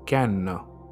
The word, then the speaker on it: Cannes: Coming to the performances, that 8-minute standing ovation that Fraser got at the Cannes Film Festival, and I googled on how to pronounce that festival's name, and it is indeed Cannes.